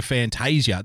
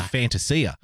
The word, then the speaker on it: Fantasia: Arazio Fantasia, that's not even how you say his name. It's Fantasia.